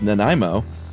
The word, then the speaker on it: Nanaimo: It is pronounced Nanaimo.